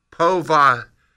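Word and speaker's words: povitica: The name of that thing is pronounced povatitsa.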